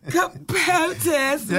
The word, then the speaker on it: POTUS: POTUS, yeah. Rhymes with? – Protest.